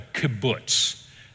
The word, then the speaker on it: kibbutz: And the name for these communal establishments are called a kibbutz. And that's how you say it.